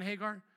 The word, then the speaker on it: Hagar: Did I say that correctly, Sarah and Hagar? Okay, good.